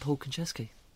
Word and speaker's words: Konchesky: His name is Paul Konchesky. Am I saying that right? Paul? – Yes, you are indeed. It is none other than Paul Konchesky.